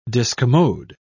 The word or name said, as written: discommode